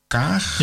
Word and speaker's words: Kaag: Eh, de twee, we hebben twee ministers van Buitenlandse Zaken, dus Zijlstra en die mevrouw Kaag, ja. zeg ik dat goed? Ja, dat zeg ik goed.